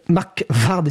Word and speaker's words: Markvard: Donc Mark Vard, Mark Vard excusez-moi, ça s'écrit M-A-R-K-V-A-R-D.